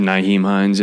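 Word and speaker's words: Nyheim Hines: And then I think you're down to Naheem Hines. Is that how it's said? – I said Naheem Hines. Yeah.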